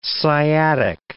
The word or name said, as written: sciatic